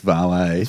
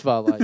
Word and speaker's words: vale: So we always say Vale on our radio show as a joke, Vale, and we always get tweets. – Oh. – It's valet. – It's valet, is it? – Right.